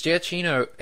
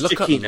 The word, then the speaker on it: Giacchino: It's interesting that you guys say that, though, because Giacchino, his past work is all brilliant. – I think it's Giacchino. Lost. I think it's Giacchino. – Is it Giacchino? – I used to call him Giacchino, but yeah, I think when I was...